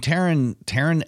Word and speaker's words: Taron: I mean, Taron Egerton, is that how you say it? Taron? – Taron, yeah. – Taron.